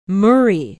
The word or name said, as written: murry